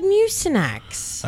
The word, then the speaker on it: Mucinex: You said Mucinex. – I said it right. – You've never said it right. Wow. I don't even know what the fuck you actually call it. – Yeah. – I don't right now. I should have wrote it down because it's been 12 years. It's never been Mucinex.